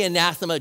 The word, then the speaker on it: anathema: But let's all say anathema generally out loud so you know how to say it.